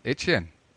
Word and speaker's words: Etienne: Man hat jetzt sich von Jacksonville, ich muss die neue Aussprache nachschauen, man entschuldige mich, dass ich noch Travis Etienne sage. Wie war das? – Etienne. Französisch quasi.